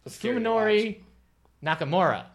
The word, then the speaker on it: Fuminori Nakamura: The Kingdom by Fuminori Nakasuma. You know what? That's not how you say it. – Your eyes crossed a little bit when you said that. – Fuminori Nakamura. – Okay.